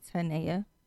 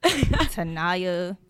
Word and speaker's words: Tanaya: They be like, Tanya. Tanaya, like, where the fuck is the I?